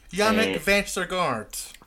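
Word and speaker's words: Jannik Vestergaard: Yannick Vestergaard.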